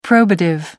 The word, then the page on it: probative: American English